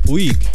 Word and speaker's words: Puig: Puig.